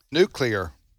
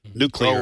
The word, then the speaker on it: nuclear: Remember, it has to be three syllables. Nuclear.